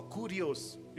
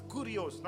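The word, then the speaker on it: kurios (κύριος): The root word for Lord in Greek is kairos or kurios. You can it's kairos, but it's pronounced as kurios, not Korean. Kurios.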